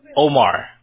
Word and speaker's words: Omar: Omar.